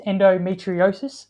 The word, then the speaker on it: endometriosis: You've been extremely open and honest about, in particular, your battle with, and correct me if I'm wrong in saying this, endometriosis, yeah. – I think is the pronunciation.